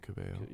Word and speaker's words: Cabello: Something Caballo... – Camila Cabello.